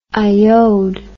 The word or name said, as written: iod